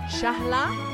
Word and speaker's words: Shahla: Shahla is the Arabic pronunciation.